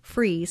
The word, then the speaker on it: Fries: Go to Sue, just like it sounds and spells, Sue Free, spelled like fries, spelled like fries, like French fries. Sue Free, spelled like fries, one word, .com.